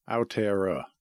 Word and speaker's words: Aotearoa: Aotearoa, I believe is how you say it. That's the cool. Maori word for it.